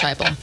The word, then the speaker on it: Heupel: Yeah, Josh Heupel.